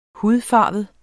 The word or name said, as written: hudfarvet